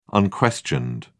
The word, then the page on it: unquestioned: British English